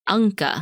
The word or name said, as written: Ancha